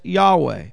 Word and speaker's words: Yahweh: It is spelt Yud-Heh-Vav-Heh. We say it as Yahweh.